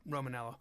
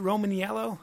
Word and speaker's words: Romanello: So, anyway, welcome to the Brian Callen Show, Adam Bornstein and John Romanello. Is it Romaniello? – It's just Romanello. The eye is silent. It's tricky. – One of those annoying fucking eyes. – Just hanging out in the middle, so there, annoying.